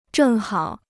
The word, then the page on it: 正好: zhèng hǎo